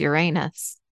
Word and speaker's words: Uranus: Uranus, which you don't want to pronounce correctly. It's Uranus.